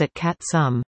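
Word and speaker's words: Átl’ka7tsem: The region, known as Atielka 7 Sem, pronounced at Kat Sum, in Squamish, falls within the nation's traditional territory, joining a network of fjords that lie just northwest of Vancouver.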